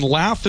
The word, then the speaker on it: Lath: Okay, and Lath is spelled? – L-A-T-H. – L-A-T-H. We dropped the E. – No E. – Okay, L-A-T-H. She was coaching me up on this, and it's like bath. She said it's like bath. Lath, L-A-T-H. – Forks Lath. – And Forks is F-O-R.